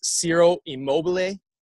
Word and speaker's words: Immobile: Ciro Immobile, is that how you pronounce his last yep.